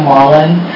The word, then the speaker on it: Wallan: My name is Kim Wallen. My last name is spelled W-A-L-L-A-N.